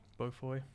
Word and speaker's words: Beaufoy: Beaufoy.